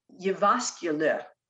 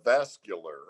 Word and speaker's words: Jyväskylä: And you'd never know it from looking at how it's spelled, you know. So believe me, I use every online source I can to get audio on pronunciations. – And I would have called it the Sinfonia Finlandia Vascular. – Yeah, looks like that.